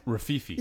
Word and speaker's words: Rififi: Also, I just want to mention, I want to pronounce it right because you gave me the DVD and I've yet to watch it. Rififi? – Rififi. – Yeah, that's what I said. Rififi. – Rififi. – Rififi. What's Wi-Fi? – Rififi? R-I-F-I-F-I.